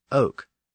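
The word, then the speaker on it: oc: In the north, oil eventually became oui, and in the south, the word for yes was pronounced oak. So those dialects became known as the Languedoc, literally the languages of oak.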